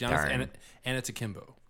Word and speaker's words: Giannis Antetokounmpo: No, it is not Giannis Bloganis. It is Giannis Antetokounmpo. Can you say that? – Giannis Antetokounmpo? – Yeah, you got it right.